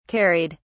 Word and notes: carried: British English

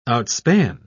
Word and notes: outspan: British English

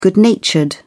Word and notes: good natured: British English

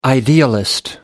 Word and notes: idealist: American English

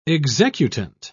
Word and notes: executant: British English

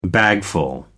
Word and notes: bagful: American English

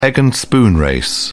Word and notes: egg and spoon race: British English

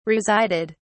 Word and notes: resided: British English

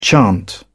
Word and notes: chant: British English

